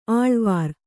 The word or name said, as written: ಅಳವರಿ